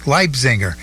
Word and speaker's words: Leipziger: Now, you're working with Karen Lippinger. – Leipziger. – I always mispronounce her name. I just can't. – That's okay. Yeah. There's a city in Germany called Leipzig. That's what the Leipziger. That's her name.